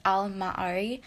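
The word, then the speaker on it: al-Ma'arri: So Al-Ma'ari, by the way, it's spelled A-L and then M-A dash, not dash, like apostrophe, A-R-R-I. And I think that's pronounced Al-Ma'ari, so that's how I'll be saying it.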